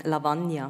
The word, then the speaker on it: Lavanya: Aber ich habe auf Deutsch Lavanya. Also Betonung auf N, Lavanya.